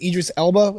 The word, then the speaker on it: Idris Elba: I can't pronounce it. It's Idris Elba, is it? – Yep, that's right.